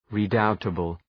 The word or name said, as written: redoubtable